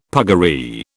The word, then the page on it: puggaree: British English